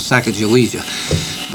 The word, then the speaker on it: Sacagawea: And the boat capsized, and the box that had Lewis's journal in began to float down the river, and Sacagawea, it's pronounced in different ways. That's the way we learned it when I was in school, Sacagawea.